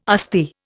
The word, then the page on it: Asti: Indian